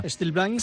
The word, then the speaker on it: Still Blind: Bueno, ya si nos vamos a un ámbito más anglosajón o americano sería... – ¿Still Blind? – Still Blind. – Con ese toquecito, sí. Con esa T.